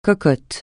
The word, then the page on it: cocotte: francais